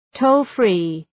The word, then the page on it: toll free: ‘təʋl,fri: